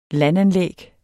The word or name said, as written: landanlæg